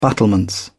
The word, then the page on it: battlements: British English